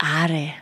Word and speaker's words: Aare: Das Are ist, da habe ich letztens auf Instagram gepostet, In der Schweiz gibt es einen Fluss, das ist die Aare. Die wird genau gleich ausgesprochen.